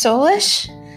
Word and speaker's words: Solais: One of my favorite words is actually in Gaelic, which is a language that is spoken in Ireland and Scotland, and it's pronounced Solish. Solish? – Oh. – But it's spelled Solais, and it means light.